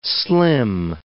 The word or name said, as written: slim